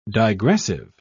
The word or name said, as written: digressive